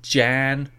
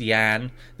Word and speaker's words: Jan: Iris, Jan. Sorry, it's pronounced Yan. They actually didn't make that clear. They put Yan underneath it, so the J is a hard J, soft J.